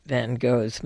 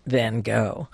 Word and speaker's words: Van Gogh: The American pronunciation is Van Gogh. It would be a little bit like calling Paris by its French pronunciation. As an American, it would be sort of uncomfortable to do so. And the Dutch are very accepting of the fact that we mispronounce his name. The Dutch pronunciation is so complicated. As one of Vincent's non-Dutch friends said, it sounded like a lot of spitting and coughing.